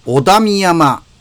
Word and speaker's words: 小田深山: さあ、今週はですね、えー、ふか、えっ、ー、と、小さい田んぼの深い山と書いて、小田見山。